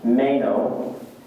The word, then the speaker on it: meno: It's a Greek word, it's pronounced meno.